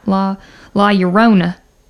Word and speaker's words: La Llorona: And is going to take over Hannah's spot this week and tell you all about La Llorona. – Yes, we can pronounce it. And Coronas. – We can pronounce it just like our neighbors here down south. La Llorona.